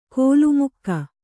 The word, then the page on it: ಕೋಲುಮುಕ್ಕ: Kannada